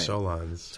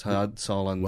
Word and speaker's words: Solondz: which stars Jordan in Todd – I always mispronounce his last name. – Solondz. – Todd Solondz.